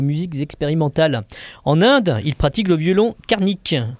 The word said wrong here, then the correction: carnatique: carnatique, pardon. Ouais, il me semblait qu'il me manquait une syllabe.